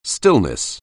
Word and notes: stillness: anglais

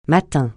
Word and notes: matin: francais

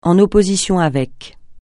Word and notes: en opposition avec: français

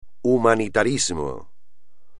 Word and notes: humanitarismo: espagnol

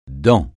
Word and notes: dans: francais

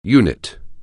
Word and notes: unit: anglais

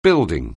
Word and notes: building: anglais

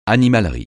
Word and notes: animalerie: francais

